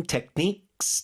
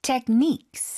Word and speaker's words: techniques: You learn about the safety rules first and the breathing techniques. Technique 발음이요. – Techniques. – 뒤쪽에 힘 주시고 techniques.